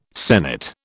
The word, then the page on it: senate: British English